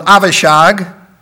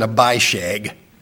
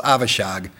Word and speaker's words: Avishag: That's a nicer way to pronounce the name, isn't it, than Abishag? I just couldn't think, can you pronounce this Abishag? And so I'm going in Hebrew, and we know it's Avishag.